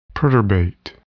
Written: perturbate

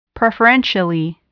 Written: preferentially

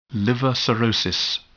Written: liver cirrhosis

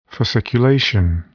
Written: fasciculation

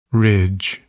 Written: ridge

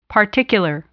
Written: particular

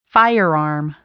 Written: firearm

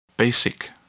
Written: basic

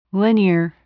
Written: linear

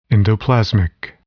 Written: endoplasmic

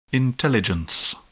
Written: intelligence

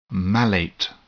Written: malate

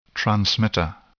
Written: transmitter

